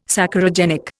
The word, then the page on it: Saccharogenic: Indian